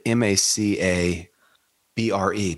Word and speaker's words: macabre: she also said, how do you say the word M A C A B R E? Did I just misspell it? Macabre. Macabre. Right. Sarah Jane said macabre.